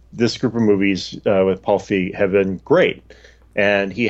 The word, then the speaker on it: Feig: How do you say his name? – Paul Feig.